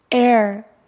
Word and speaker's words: heir: heir, heir. – 후계자. – heir는 후계자. h에다 묵음이라고 쓰세요. h는 읽지 않는데요.